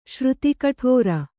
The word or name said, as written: ಶ್ರುತಿಕಠೋರ